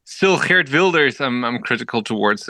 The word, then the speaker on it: Wilders: And even in the Netherlands, we've got Geert, how do you say his name? Geert de Villiers? – Geert Wilders. – Geert Wilders.